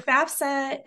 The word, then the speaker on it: FAFSA: Now, with circling back to FAFSA, is that how you say it, FAFSA? – Yep.